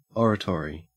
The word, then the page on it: oratory: Australian English